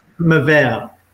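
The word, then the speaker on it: Mebea: Is it Meba? Is that how you pronounce it? Amoeba? Meba Robin? – Mavel. – So okay. I've mispronounced something. Yeah, I always mispronounce something in an episode, so I've made that achievement. I mean, tell us about just how you got this car. – It's a Greek word, so that, you know, it's a Greek pronunciation.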